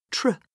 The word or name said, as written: tr